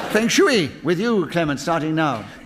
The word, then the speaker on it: Feng Shui: Feng Shui, as Linda told us it should be pronounced,